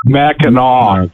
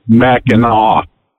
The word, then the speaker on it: Mackinac: Mackinac. It's pronounced Mackinac, not Mackinac.